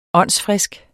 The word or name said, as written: åndsfrisk